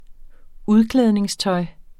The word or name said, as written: udklædningstøj